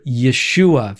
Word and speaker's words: Yeshua: It's all to say that that Jesus is Joshua, even though all the J's should be pronounced with a Y and so on. Now, why do we hear Yeshua very often? So people who want to say his name more authentically, like myself, depending in the context that I'm in, the Hebrew is often represented as Yeshua.